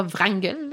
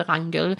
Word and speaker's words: Wrangel: on met un W devant, mais généralement, nous, en français, on, ne le prononcera pas. on ne dira pas Wrangel. En anglais, vous allez peut-être l'entendre, mais genre, euh, on va dire lire de Wrangel. Euh, ça va fonctionner. ça avait été découvert quand même dans l'océan Arctique depuis quelques décennies déjà, mais personne n'avait vraiment exploré les lieux.